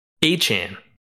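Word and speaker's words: Achane: They are getting, you know, I, I called him A chain for a solid eight weeks. It's actually pronounced A chain.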